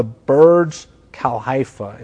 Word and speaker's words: Burj Khalifa: And I think it's called the Burj Khalifa is how you pronounce that that skyscraper.